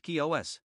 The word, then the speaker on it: Chios: The following night, they apparently anchored off the island of Chios, pronounced Kios.